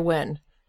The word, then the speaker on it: Nguyen: Andrea Wynn, which is definitely not how I would have pronounced N G U Y E N.